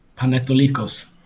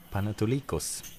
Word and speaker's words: Panaitolikos: Um, ja, men livet går vidare och eh, den grekiska klubben Panaitolikos är... Ja. Jag har förmodligen inget vidare uttal. Eh, vill du sätta uttalet här? Panetolikos. Panetolikos. Okej. – Okay. Ja, nära.